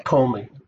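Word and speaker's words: Ptolemy: Umishum ma'isa de Talmai HaMelech, because of the following story of King, how do you pronounce it in English? Talmi? Talmi the right translation? Okay. – Talmi. He is silent.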